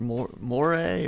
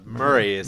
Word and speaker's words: Moray: You go through Banff, you go through kind of all these towns, and is it Moray or... – Murray? How the locals pronounce it. It's spelled more like a moray eel.